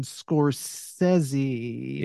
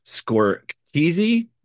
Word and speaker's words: Scorsese: Martin Scorsese, Yeah. – 2023. – Or as one of my students pronounced this last semester, score keezy.